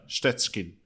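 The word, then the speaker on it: Szczecin: Ja, det står ju här Stettin. Jag rådfrågar hur man ska uttala det. Ja. Egentligen ska det vara mer så här Stetschkin.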